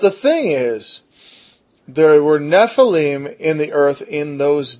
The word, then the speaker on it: Nephilim: Now the word giants is the Nephilim, or Nephilim, as my old teacher used to say, or Nephilim, actually, would probably be the proper pronunciation. Nephilim, not Nephilim.